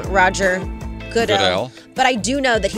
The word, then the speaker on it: Goodell: Roger Goodell.